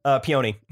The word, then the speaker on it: peony: Peony. – Peony. – Yeah. Girl would have been great to have in the room. – Actually, it's peony.